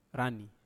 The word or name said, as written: rani